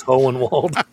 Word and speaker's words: Hohenwald: Died last Friday, August 4th, at the Tennessee Elephant and Wildlife Rescue in Hohenwald, Tennessee. Did I say that right? – Yep. Hole in the wall, yeah. – Yeah. No, it's Hohenwald.